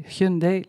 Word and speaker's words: Hyundai: Wij zeggen in Nederland veel Hyundai, maar je spreekt het echt uit als Hyundai. Hyundai, like Sunday.